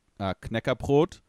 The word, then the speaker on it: Knäckebrot: It's called Knäckebrot, and that's like this like cracker bread. So that's basically what it means. Like cracky bread, Knäckebrot. So that's why I started with the KN. I pronounced it.